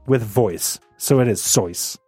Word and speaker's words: Seuss: If you're calling him Seuss, he pronounces it Seuss." – Rejuice. – Yeah, because in German, the S's come out as Z's.